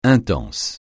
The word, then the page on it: intense: francais